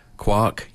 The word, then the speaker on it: quark: Quark, yeah, because then that rhymes with lark and that's the gag, isn't it?